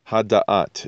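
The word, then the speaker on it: Hadat (הדעת): All right, so I'd like to welcome Eitz Hadat. Hadat is the proper way to say that.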